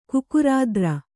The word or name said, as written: ಕುಕುರಾದ್ರ